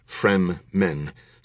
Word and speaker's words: Fremen: The name Fremen, pronounced Frem-men, sounds close to Freemen, a suggestion that they are an independent, rebellious tribe who will never permit themselves to be dominated by outsiders.